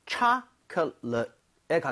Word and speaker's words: chocolate: chocolate 할 때는 앞에 오의 강세가 가죠. 그러면서 입 모양이 좀 벌려지겠죠. 그래서 초콜릿보다는 chocolate에 가깝고요.